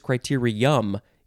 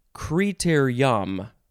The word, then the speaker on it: criterium: So the last word was criterion, O-N, this is criterium, U-M. And you can also pronounce it criterium. What? So you can say criterium or criterium. Noun from 1970,